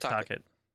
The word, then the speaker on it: Tocchet: the fans are gonna get behind Troche. Is the new coach. – No. – And it's like, guys. – Rick Tocchet. – Oh, yeah. I think I just pronounced it wrong.